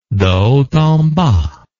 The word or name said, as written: de haut en bas